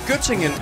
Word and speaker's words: Göttingen: Var det ved Gødringen? – Nej, Göttingen.